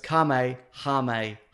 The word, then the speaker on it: Kamehameha: So it's Kamehameha.